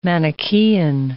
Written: Manichean

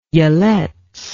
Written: Yelets

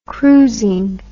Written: cruising

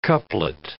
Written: couplet

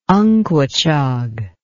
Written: unquachog

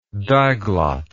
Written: diglot

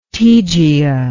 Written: Tegea

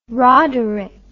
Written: roderic

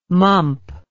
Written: mump

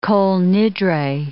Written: Kol Nidre

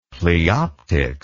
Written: pleoptics

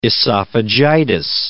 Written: esophagitis

